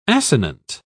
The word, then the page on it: assonant: British English